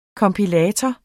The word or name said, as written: kompilator